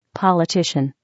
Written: politician

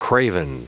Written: craven

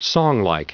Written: songlike

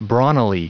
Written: brawnily

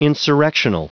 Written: insurrectional

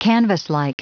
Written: canvaslike

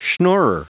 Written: schnorrer

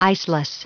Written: iceless

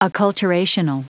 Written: acculturational

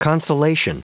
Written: consolation

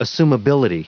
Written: assumability

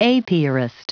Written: apiarist

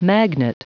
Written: magnet

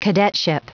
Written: cadetship

